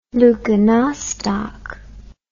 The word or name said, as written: leuconostoc